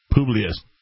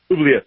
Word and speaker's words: Publius: Publius. Publius with an S, like Sierra.